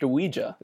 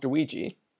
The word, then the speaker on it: Ouija: Also, just learned recently, it's pronounced Ouija. It's not pronounced Ouija.